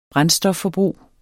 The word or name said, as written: brændstofforbrug